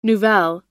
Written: nouvelle

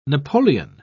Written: napoleon

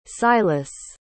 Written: Silas

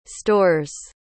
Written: stores

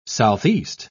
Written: southeast

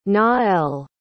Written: naël

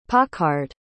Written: pockarred

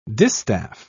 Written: distaff

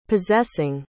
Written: possessing

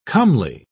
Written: comely

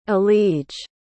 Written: elige

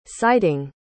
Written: sighting

